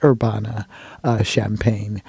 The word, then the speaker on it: Urbana-Champaign: She's also an associate professor of piano at the University of Illinois at Urbana-Champaign. And that's not campaign or champagne. It's spelled like campaign with an H.